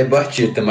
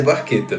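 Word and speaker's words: Barchetta: É, no shows o que a gente fala é Barcheta, mas a pronúncia certa é Barqueta mesmo.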